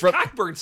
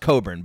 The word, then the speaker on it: Cockburn: The thing that me and my friends always like, because Cockburn sucks. – Cockburn would suck, but there's got to be a joke in that name, Kofi Cockburn, right? – Yeah. – I mean, it's pronounced Coburn, but like, the best I could come up with is Kofi makes my Cockburn.